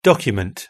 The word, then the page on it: document: British English